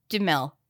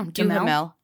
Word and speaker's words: Duhamel: What's confusing to me is that I forgot, every time I forget how to pronounce Josh Duhamel's name. Duhamel? – No, Duhamel. – I thought it was Duhamel.